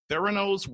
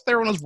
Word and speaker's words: Theranos: He is an entrepreneur of Theranos Whistleblower. What's Theranos Whistleblower? – Yes.